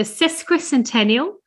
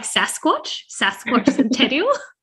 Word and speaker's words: sesquicentennial: sesquicentennial, or 150 years of British settlement of Tasmania. – Great pronunciation there, man. – Oh, definitely had to practice that a couple of times. I was like Sasquatch Centennial.